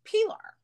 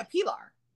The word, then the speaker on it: Pilar: So my name is Pilar. – Pilar. – Yeah, Pilar. Okay, nice. P I L A R. Pilar. Um, but the, and that's how it's pronounced, Pilar. Pilar.